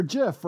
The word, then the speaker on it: GIF: it's like GIF or JIF, right? I mean, the accepted pronunciation is GIF now. But if you talk to the person who created it, he says JIF.